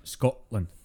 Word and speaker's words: Scotland: but I think what what uh, damned Mel Gibson's is that he made the, the fatal mistake of trying to pronounce the T in any way, shape, or form in the word uh, Scotland. Yeah. We don't do it. It's a uh, Scotland.